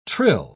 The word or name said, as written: trill